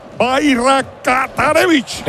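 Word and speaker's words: Bajraktarević: Bayraktarević. – Le podemos llamar Esmir, que es el... – Lama, puedes decir Barata y luego pones Revic.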